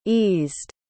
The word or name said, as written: eased